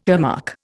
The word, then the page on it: earmark: Indian